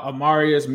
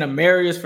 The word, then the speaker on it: Amarius: We didn't get to talk about Amarius Mims. I've been saying Amarius for so long, I got to say his name properly.